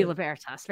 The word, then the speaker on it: Libertas: It's like Italian. Libertas. – Italian always puts the stress on the second to last syllable. So. Would be Libertas.